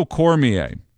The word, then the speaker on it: Cormier: Daniel Cormier shared screenshots of a DM message, personal messages between himself and Eric Trump that I am going to now read for you.